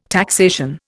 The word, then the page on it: taxation: Indian